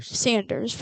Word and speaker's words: Sanders: Barry Saunders. – Sanders, whatever.